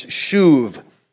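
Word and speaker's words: shuv (שוב): Now, that word restore or repair, in the Hebrew, it's shuv. The word shuv, that B is actually pronounced with a V pronunciation.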